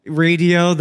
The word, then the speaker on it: Raydio: Radio, his band, which is spelled R-A-Y-D-I-O.